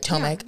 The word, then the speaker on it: Tomic: So it's just funny that the when Renee sent this article about Tomek, am I saying that right? Tomek. Yeah.